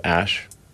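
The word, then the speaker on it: Æ: I mean, it's just X, the letter X. The AE is like pronounced Ash.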